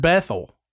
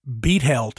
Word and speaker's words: Bethel: So, even Adam had mentioned the Honorable Richard Bethel, or I've heard it pronounced Beethel too. Heard it pronounced both.